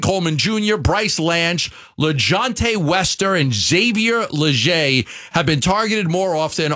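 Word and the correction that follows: Legette: Did I pronounce the name wrong? – Leggett. – Yeah, okay, Xavier. Close enough. I went with the French version.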